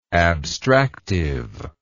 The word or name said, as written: abstractiveness